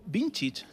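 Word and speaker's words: Vinčić: Es el esloveno Slavko Vincic.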